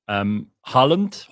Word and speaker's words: Haaland: So if you haven't heard of Haaland, as they pronounce it in Norwegian, this guy is like, he's the weirdest.